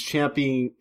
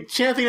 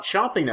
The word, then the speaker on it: champing: Because usually I'm the one who's champing at the bit. – That's actually the... – For them divas. – I learned today, champing at the bit is how you say it. It's not chomping at the bit.